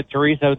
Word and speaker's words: chorizo: I've done spaghetti, and I think it tastes better with the chorizo than I do with the ground beef.